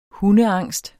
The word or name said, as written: hundeangst